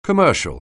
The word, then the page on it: commercial: anglais